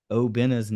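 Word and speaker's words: Obinna: been mispronouncing Obina's name his entire time here at Marshall. He says his name is pronounced Obina.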